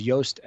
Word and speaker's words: Yoast: Yeah, the kind of go-to in the industry right now is Yoast SEO. That's spelled Y-O-A-S-T. So almost like toast, but with Y.